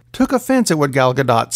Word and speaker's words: Gadot: Speaking of powerful women, Wonder Woman herself, Gal Gadot, because you pronounce the T, had some comments about the passing of Dr. Stephen Hawking, which was sad.